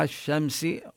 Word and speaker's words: الشمس: مطلع الشمس وهنا اللام بالشمس لا تلفظ إنما تدغم بالشين لذلك شددوا الشين.